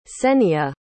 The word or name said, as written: Kseniya